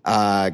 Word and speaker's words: Guy: For our American audience, in Montreal, every player's names are pronounced, especially if they're French Canadians, are pronounced in their phrase. So, like Guy Lafleur, Patrick Roy, see, I did it. Maple Leaf fan wouldn't call Guy, would say Guy, because that's the, you know, Anglophone way of saying it.